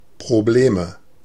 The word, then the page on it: Probleme: /pʀoˈbleːmə/